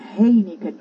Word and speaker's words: Heineken: No se dice Heineken, No, Heineken con H.